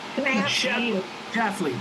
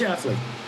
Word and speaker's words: Schlafly: Activist Phyllis Schlafly, that's a tough name to pronounce. – Schlafly. – Oh, yeah, please jump in. Please jump in. – Phyllis Schlafly. – Oh, there you go.